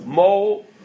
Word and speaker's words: Mohorachash: And the Mohorachash, that's the way you pronounce it, is quoted by the Shita Mikubel, the Mishnah Melech, is sides with the Rashba that we say, Halacha L'maisa, that you're possible.